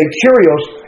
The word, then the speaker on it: Kyrios: He said that word Lord there is the Greek word Kyrios, spelled with a K, Kyrios, and it's what in the first century you called the Caesar.